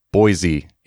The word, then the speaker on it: Boise: Oh, it's like an S, not a Z ish. Yeah, it's like it's a hard S there, not Boise.